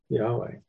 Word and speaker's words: Yahweh: So Jehovah is a German pronunciation of Yahweh. So that's where it came from. The J in what we transliterate is, or translate as a J in Hebrew is a Y sound.